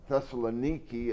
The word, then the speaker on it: Thessaloniki: So the point is that Thessaloniki, as it's called in the way that people pronounce it over there, is 2,300 years old.